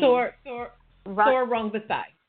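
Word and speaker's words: Sor Rungvisai: What's the guy's name that beat Chocolatito? I don't want to butcher his name. – Thor Rungvisai.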